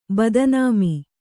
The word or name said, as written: ಬದನಾಮಿ